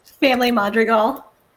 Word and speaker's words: Madrigal: I'm not too drunk to say this correctly. What is it? – Family Madrigal.